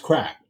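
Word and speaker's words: craic: I think that is spelled C-R-A-I. – Yeah, but it's pronounced crack. – Yeah, it is pronounced crack, which is a good time.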